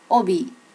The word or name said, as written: obi